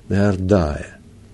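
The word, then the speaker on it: Наардая (נהרדעא): А мудрецы города Наарде, на, Наарде, мы так признаемся, классическое произношение должно быть Наардая – Нарная, так говорят.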